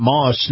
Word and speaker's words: Maus: Moss is spelled M-A-U-S.